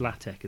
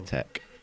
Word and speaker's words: LaTeX: It's actually pronounced LaTeX as well. I didn't know that before. – Yeah, tech.